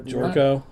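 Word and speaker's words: Gyorko: How do you pronounce his last name? Like the Euro sandwich? You know, Jorko.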